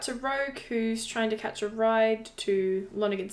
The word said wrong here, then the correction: Laughlin: City, which oh, Laughlin. she catches. – Sorry, Laughlin.